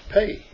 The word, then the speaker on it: peh: And mouth there is 6310, and it's pay. P-E-H, pay, as it's pronounced.